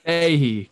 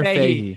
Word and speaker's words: Fahey: Fahey. Connor Fahey. Fahey. – I kept saying Fay, so I was like, today I'm here with Connor Fahey, the Star Wars guy on TikTok.